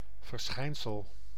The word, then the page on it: verschijnsel: /vər.ˈsxɛi̯n.səl/